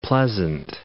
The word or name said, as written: Pleasant Island